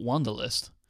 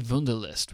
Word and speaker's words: Wunderlist: I assume it's actually Wunderlist, right? Not Wunderlist. I've just realized. – You live closer to Germany than I do. – Yeah, because the company's name is Six Wunderkinder, because, you know, W's are pronounced that way in Germany.